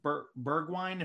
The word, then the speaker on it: Bergwijn: All right, we move to the attacking midfielder positions, and it was Bergwijn, if I'm saying that correctly.